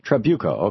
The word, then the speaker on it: Trabuco: Am I saying that right? – Trabuco. – Okay, great.